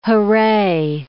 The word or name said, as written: hurray